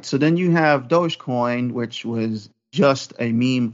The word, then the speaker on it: Dogecoin: And it's spelled doggy coin, but it's Dogecoin.